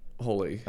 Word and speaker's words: Holi: So it's pronounced Holi? – Holi.